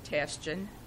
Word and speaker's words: Tashjian: Can you just give Robin's spelling of her first It's R-O-B-I-N-T-A-S-H-J-I-A-N. – How do you pronounce that? – Tashjian.